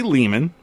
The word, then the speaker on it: Lehman: So Ari Lehman rhymes with demon. That's how that's what he says.